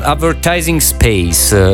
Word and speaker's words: Advertising Space: Allora, Robbie Williams con Advertising Space.